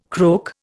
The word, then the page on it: croak: Indian